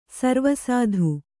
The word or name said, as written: ಸರ್ವಸಾಧು